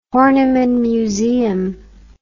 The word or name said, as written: Horniman Museum